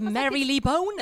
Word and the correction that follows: Marylebone: It's Marylebone.